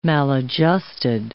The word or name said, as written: maladjusted